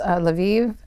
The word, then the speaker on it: Lviv: So next is Lviv.